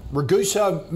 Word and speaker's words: Ragusa: All right, Jack wants a view on Ragusa Minerals. Is that how you pronounce it, Carl? – Yes.